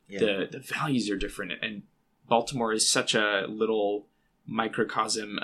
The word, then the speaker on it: Baltimore: And moving to Baltimore, I, you know, listeners to the podcast will know that I'm not a true Baltimorean because I pronounce it with the hard T instead of Baltimore.